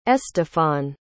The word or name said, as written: estefan